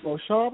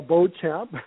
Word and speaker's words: Beauchamp: Well, my name is Keith Beauchamp, of course, or Beauchamp, the way you pronounced it, but I'm the executive producer and the host of the Injustice Files on Investigation Discovery.